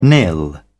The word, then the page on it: nil: British English